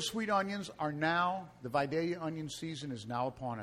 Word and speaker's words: Vidalia: Pronounced the proper way. – Vidalia.